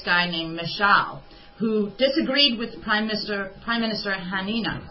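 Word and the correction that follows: Haniyeh: Let me make sure I said his name right. Hania.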